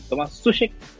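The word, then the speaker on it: Tomáš Souček: En el medio va a estar Declan Rice, que creo que está para dar salto a un equipo más grande, y Thomas Sush, ¿cómo lo pronunciamos? Thomas que está siendo uno de los jugadores con. Sí, Tomás Sushek,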